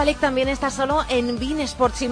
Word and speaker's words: beIN Sports: Sí, se escribe Bein, pero aquí se pronuncia Bein Sports.